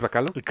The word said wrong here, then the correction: Bachalo: Que ya me enteré que es Bachalo. – Bachalo. – Dice el buen Beto Calvo que el nombre, como se pronuncia, es Chris Bachalo.